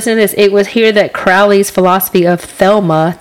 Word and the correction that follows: Thelema: T-H-E-L-E, Thelema, I'm sorry, I'm saying it wrong, which sounds like those thetas, but, excuse me, I knew that was going to happen.